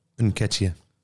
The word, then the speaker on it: Nketiah: Nketiah.